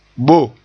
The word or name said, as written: bo